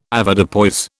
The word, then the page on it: Avoirdupois: British English